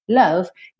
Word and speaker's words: love: Sempre lembrando que love, love, não se pronuncia a letra E. Então você não fala love, love, não, love. Para na letra V.